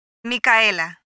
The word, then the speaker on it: Micaela: Micaela. – How do you spell it? – M-I-C-A-E-L-A. – M-I-C-A-E-L-A.